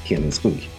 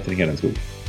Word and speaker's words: Hedenskog: Yeah, I say it Hedenskog. Peter Hedenskog.